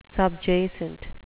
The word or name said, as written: subjacent